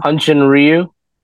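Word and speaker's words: Hyun-jin Ryu: Hunjin Ryu.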